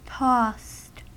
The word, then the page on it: tossed: American English